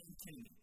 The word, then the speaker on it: antinomy: We're not talking about antimony. I'm going to pronounce it differently. We're talking about an antinomy. An antinomy. Spelled the same, slightly pronounced differently.